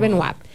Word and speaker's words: Benoit: Benoit, apellido francés. – Roberto Benoit, en La Vega. Benoit, se pronuncia Benoit.